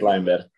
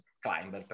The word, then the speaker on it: climber: Allora, io so che tu, poi se sbaglio la pronuncia, dimmelo, Climber o Klimber, qual è la pronuncia corretta? – Climber. – Climber, perfetto. Do sempre la seconda opzione, ma una sulle due le prendo.